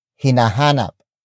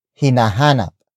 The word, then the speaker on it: hinahanap: The next word is hinahanap. Hinahanap. Hinahanap. Hinahanap is spelled as H-I-N-A-H-A-N-A-P.